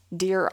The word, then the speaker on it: Deir: It is the archaeological dig site Deir Allah, that's pronounced deer, I guess, but it's spelled D-E-I-R, and then the second word is A-L-L-A,